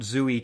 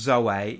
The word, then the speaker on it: Zoe: he attends Zoe Church in Los Angeles, not Zooey Church, to be confused with Zooey Deschanel's Zooey Deschanel. Church. It's pronounced Zoe.